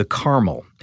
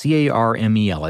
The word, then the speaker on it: Carmel: This is a model called the Caramel, and it's Carmel. It's caramel. It's not caramel like the candy.